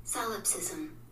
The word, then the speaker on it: solipsism: Solipsism.